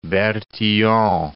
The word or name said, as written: Bertillon